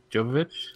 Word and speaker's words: Jovovich: So alongside this, I feel like pretty much alongside this, they announced the Monster Hunter movie that they are working on, and Mila, I'm gonna butcher this Jovovich. – last name, Jovovich. – Yes.